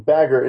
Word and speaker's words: Bagger: And on the same note, the A in Bagger is pronounced the same way as the one in my name. like the A in LARP or the U in the word us. Well, Florian, those, those are two different sounds.